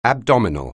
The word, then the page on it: abdominal: anglais